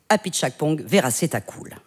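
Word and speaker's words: Apichatpong Weerasethakul: ce serait Cemetery of Splendor du Thaïlandais Apichatpong Veerasethakul.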